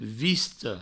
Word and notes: vista: visto

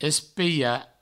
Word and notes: espelhar: provençal; espeia; espiha

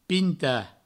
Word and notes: pintar: pinta

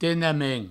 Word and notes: tenement: tenemen

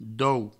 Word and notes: dou: Provençal

